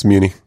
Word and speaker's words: Muni: Is it the Muni? Am I saying that right? – Correct. It's Muni. Yep.